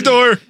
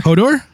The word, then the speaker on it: Eador: Edor. – E-A-D-O-R. – Hodor? – Hodor.